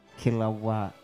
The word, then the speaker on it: Kilauea: How do you pronounce that? Kilauea or Kilo? – Kilauea. – I think it is.